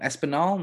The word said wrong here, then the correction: Espolón: No. – Espolón.